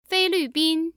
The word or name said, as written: feilvbin